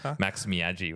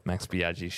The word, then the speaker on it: Max Biaggi: Max Miaggi when I was a kid. I couldn't pronounce feet. Yeah. So. – There you go. – Max Biaggi, shout out.